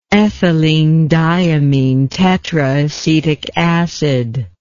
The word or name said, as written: ethylenediaminetetraacetic acid